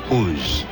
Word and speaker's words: Oz: It's spelled exactly the way Oz is spelled, O-Z, but it's pronounced Uz.